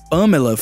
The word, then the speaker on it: Pamela: not in the word Fernanda, but we have the accent mark in the name Pamela, for example. So we have that little accent on the top of the letter A. So we say Pam, Pam, Pamela. So Pam is where the accent is.